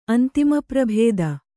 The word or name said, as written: ಅಂತಿಮಪ್ರಭೇದ